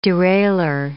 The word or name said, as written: derailleur